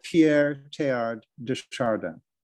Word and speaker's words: Pierre Teilhard de Chardin: but there's a chapter on Pierre Teilhard de Chardin. I didn't do that right. How do I say it? – No, not bad. Pierre Teilhard de Chardin.